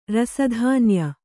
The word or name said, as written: ರಸಧಾನ್ಯ